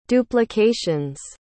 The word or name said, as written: duplications